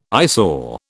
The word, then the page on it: eyesore: British English